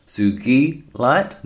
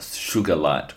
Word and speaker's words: sugilite: So sugi, like geese, sugi light, not sugar light or anything like that. So the first thing, this is obviously being a violet crystal, it's going to be really great for the crown chakra.